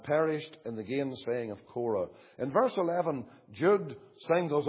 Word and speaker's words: Korah: There's Cain, and there's Balaam, and there's Korah. Spelt here C-U-R-E. It's pronounced Korah.